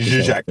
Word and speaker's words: Žižek: Slavoj Žižek, I think. – Žižek.